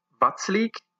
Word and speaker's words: Vaclík: De doelman van de Tsjechen heet Thomas Vaclík. Die C wordt uitgesproken als ts want er staat geen omgekeerd hoedje op.